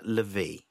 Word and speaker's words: Levy: It is Levy, isn't it? – I think it is Levy. – Levy, that's the way you pronounce it.